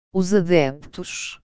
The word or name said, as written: os adeptos